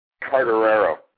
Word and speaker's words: Quartararo: How do you say that? Quartaro? – Carterero. – Carterero.